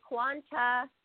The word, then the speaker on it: Kwanzaa: Happy Kwanzaa, everybody.